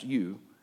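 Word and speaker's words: eu: Because the prefix you, E-U, pronounced you, it means good and pleasant. Good and pleasant.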